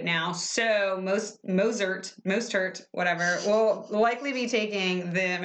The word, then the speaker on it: Mostert: I think the T is silent, isn't it? – Mostert? – Maybe. – M-O-S-T-E-R-T. Mostert. Mostert. Whatever.